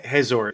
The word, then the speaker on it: Hazor: So you're saying, and I'm not gonna pronounce it the same way. Hazor, is that okay? – Yeah, it's okay.